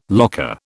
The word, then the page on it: locker: British English